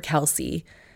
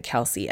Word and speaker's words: Kelce: I don't know about this pronunciation of Kelce. We had, when Fred was born, an American football ABC book, and it was K for Kelce. And even Bobby pronounces it Kelce. Announcers pronounce it Kelce, but sometimes there are mass mispronunciations of names.